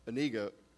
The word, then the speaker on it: Inigo: It's a story of the friendship between Fezzik and Indigo. Inigo, I'm saying it wrong.